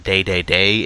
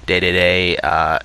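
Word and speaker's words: Dedede: And it's actually pronounced Day-Day-Day in Japanese. Everyone on the boards probably knows this already. Day-Day-Day is the way you technically should say it.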